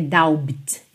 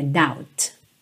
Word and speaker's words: doubt: But watching you stand alone, all of my doubt, a letra B não soa, tá? Não é doubt, é doubt.